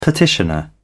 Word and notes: petitioner: British English

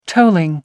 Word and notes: tolling: American English

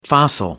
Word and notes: fossil: American English

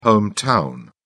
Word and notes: hometown: British English

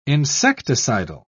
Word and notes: insecticidal: British English

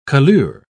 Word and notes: colure: British English

